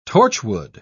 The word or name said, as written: torchwood